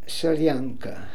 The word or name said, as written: Solianka